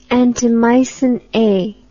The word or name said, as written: antimycin A